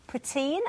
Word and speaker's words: poutine: Now, this is poutine.